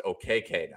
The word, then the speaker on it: Okeke: I think he wants it to be pronounced OKK now, but it's really hard for me to not call him Chuma Okeke.